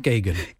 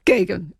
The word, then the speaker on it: Gagan: We do meet on a regular basis with the director of nursing, Sandy Gracia, and also the director, Patrick G, I'm going to say, because I never pronounce his name right either. – Well, we had to sort of go back and forth on it for a while, but Patrick Gagan. – Gagan, yes.